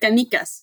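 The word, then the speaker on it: canicas: almost right. Canicus. It's the Spanish Canicus word for marbles, which has just always been one of my favorite words.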